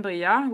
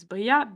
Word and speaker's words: Briat: So my full name is Violaine Bria, which is spelled V-I-O-L-A-I-N-E. That's my first name. And my last name is Bria, B-R-I-A-T.